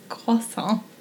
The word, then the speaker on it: croissant: Wasson? – It's not Wasson. It's croissant.